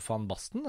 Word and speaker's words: van Basten: Han la jo opp etter hvert, men jeg husker Marco van Basten var det navnet jeg lærte meg, hvor jeg lærte at det finnes noen land hvor V uttales F.